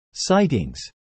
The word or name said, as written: sightings